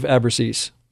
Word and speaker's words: Abruzzese: and they re-recorded it with Dave Abruzzese.